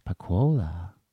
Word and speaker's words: Pacquola: And now we are back with the wonderful Celia Pacquola.